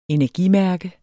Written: energimærke

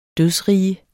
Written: dødsrige

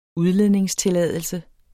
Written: udledningstilladelse

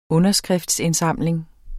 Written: underskriftindsamling

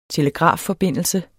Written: telegrafforbindelse

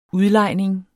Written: udlejning

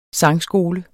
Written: sangskole

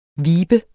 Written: vibe

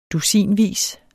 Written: dusinvis

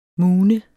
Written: moone

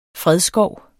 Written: fredskov

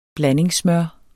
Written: blandingssmør

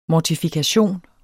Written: mortifikation

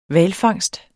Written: hvalfangst

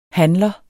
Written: handler

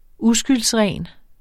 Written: uskyldsren

